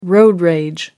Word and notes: road rage: American English

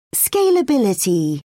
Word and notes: scalability: British English

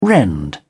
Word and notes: rend: British English

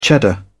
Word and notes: cheddar: British English

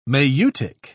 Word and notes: maieutic: British English

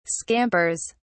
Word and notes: scampers: British English

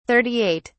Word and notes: 38: British English

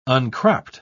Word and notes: uncropped: British English

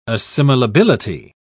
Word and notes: assimilability: British English

